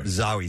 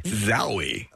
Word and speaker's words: Zawe: Her name is spelled, if you're going to look it up, Zowie, Z-A-W-E. – Zowie.